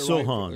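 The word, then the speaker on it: Sochan: Jeremy Shokin, if I'm pronouncing it Sohan. – Right. Sohan from Baylor.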